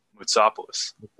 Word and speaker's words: Moutsopoulos: Moutsopoulos. – Moutsopoulos. That's right. Yeah. It's that Greek.